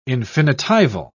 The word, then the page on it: infinitival: British English